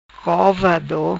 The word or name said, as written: Covado